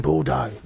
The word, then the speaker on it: Bordeaux: It's in Bordeaux. They don't pronounce the X.